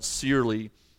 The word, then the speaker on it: Searle: In his book on the life of Joseph, I like how author David Searle, I think that's how it's spelled, Searle, or rather how it's pronounced. It's spelled Sear with L-E, so imagine that's what it is.